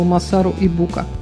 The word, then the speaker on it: Масару Ибука: Согласно российским словарям, правильное ударение на предпоследний слог, но японцы наверняка будут очень смеяться, я назову его Масару Ибука.